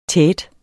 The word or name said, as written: tete